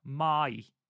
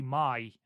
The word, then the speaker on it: Mai: So, according to the way that Japanese vowels are pronounced, the A is R and the I is E sound. So, it should be Mai, I believe.